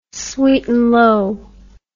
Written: sweetnlow

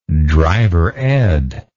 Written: drivered

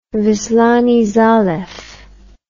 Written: wislany zalew

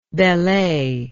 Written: Bellay

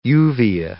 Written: uvea

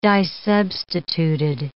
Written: disubstituted

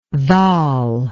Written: dhal

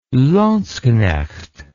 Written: landsknecht

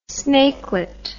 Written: snakelet

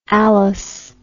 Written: allus

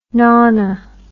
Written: nona

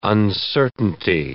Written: uncertainty

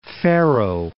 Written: Faroe Islands

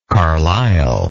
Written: carlylese

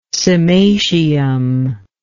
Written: cymatium

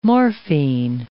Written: morphine

